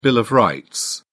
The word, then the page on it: bill of rights: British English